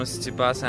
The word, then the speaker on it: Tsitsipas: This is how you pronounce his name. – I'm Stefano Tsitsipas and I'm from Greece. – Oh, from Greece! Great way to put it. 1-3-3-5-3.